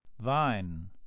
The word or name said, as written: vine